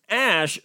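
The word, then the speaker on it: Æ: But Elon says the A-E smushed together is pronounced Ash, making the baby's name X-Ash-A-12.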